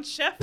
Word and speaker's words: Schiff: How do you spell it? – S-C-H-I-F-F. Audiobook says chef.